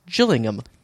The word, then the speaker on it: Gillingham: like Anthony Gillingham? – Yes, except actually the town is pronounced Gillingham.